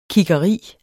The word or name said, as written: kiggeri